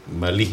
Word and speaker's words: Malí: Malí es con acento en la I.